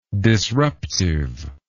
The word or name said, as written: disruptiveness